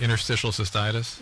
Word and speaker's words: interstitial cystitis: Interstitial cystitis? – Yes.